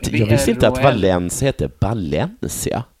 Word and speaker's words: Valencia: Tydligen så är det så att V uttalas B på spanska. – Ja, som i Valencia.